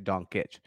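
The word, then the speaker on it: Dončić: but it's actually Doncic.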